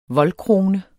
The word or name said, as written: voldkrone